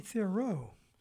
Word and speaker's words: Thiroux: And the very talented Katie Thiroux, and her last name is spelled T-H-I-R-O-U-X, Katie, K-A-T-I-E, Katie Thiroux.